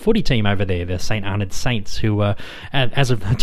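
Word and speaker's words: St Arnaud: And James did assure me during the interview that the town is pronounced St. Arnold, not St. Arnold, not St. Arnaud, like Chanel number 5. But St. Arnold, that's the Australian way of saying it.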